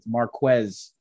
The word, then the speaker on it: Marquez: but Mr. Marquez, and that's exactly how you pronounce it, Marquez Valdes-Scantling.